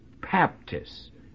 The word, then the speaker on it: Baptist: And most of us, you know, from the South pronounce it as if it's spelt B-A-B, but it's spelt, because it comes over from the Greek, it's spelt B-A-P, you see, Baptist, and it's baptizo in the Greek.